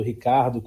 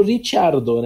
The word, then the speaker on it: Ricciardo: como a gente fala, Ricciardo, Ricardo, como ele pede para pronunciar, porque é como chamam lá na, na, na, terra, na, Austrália, mas é um, ele seria o Ricciardo, né? Lá, lá, em, lá na Itália.